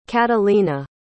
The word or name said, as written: catalina